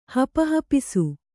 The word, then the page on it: ಹಪಹಪಿಸು: Kannada